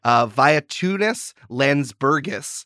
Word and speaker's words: Vytautas Landsbergis: Vytautas Landsbergis.